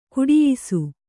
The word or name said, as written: ಘಟಿಯಿಸು